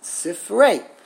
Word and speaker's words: Sifrei: So there's only one possible way to express, to pronounce it. Sifrei. Unfortunately, it's a very common mispronunciation because people do not know that.